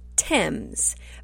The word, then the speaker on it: Thames: It's called the Thames River. Thames, but it's spelled T-H-A-M-E-S, like Thames.